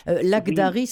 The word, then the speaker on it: Lakhdari: Laghdari, ça s'écrit L-A-K-H-D-A-R-I. King comme un king, comme un roi.